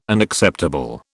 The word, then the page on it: Unacceptable: British English